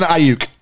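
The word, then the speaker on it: Aiyuk: I don't know how to say these names. B. Ayuk? – Brandon Ayuk.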